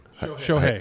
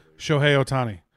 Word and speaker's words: Shohei Ohtani: Shohei. – How do you spell it? – S-H-O-H-E-I is his first name. Ohtani, O-H-T-A-N-I. Shohei Ohtani.